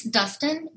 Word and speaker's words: Dustin: It's Dustin, D-U-S-T-I-N.